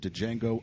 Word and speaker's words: Django: We're in season four, going through every single Leonardo DiCaprio movie, and we have arrived triumphantly to Django Unchained. Django Unchained. The D is silent.